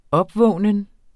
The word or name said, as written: opvågnen